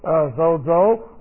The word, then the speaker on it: sozo: This is S, long O, Z, O, is one of the words. Sozo, it means save, deliver, make whole, preserve.